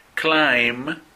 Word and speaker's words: climb: Climb, que tampoco se pronuncia la B.